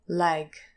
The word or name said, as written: lag